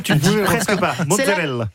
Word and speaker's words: mozzarella: Loana Belmondo, on parle de mozzarella aujourd'hui. Absolument. Déjà, est-ce qu'on prononce bien ? C'est mozzarella ? – Mozzarella. – Mozzarella. Mozzarella. – Vous le dites très très bien.